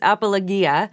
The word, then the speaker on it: apologia: And that word that Peter uses there for defense is the Greek word, and I'm going to butcher the pronunciation here, but it's apologia. And That's pretty good.